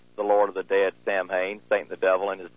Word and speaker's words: Samhain: Samhain is spelled S-A-M-A-N or S-A-M-H-A-I-N.